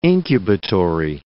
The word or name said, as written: incubatory